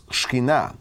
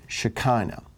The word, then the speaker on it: Shekinah: It's pronounced shkinah, or you've probably heard it shekinah.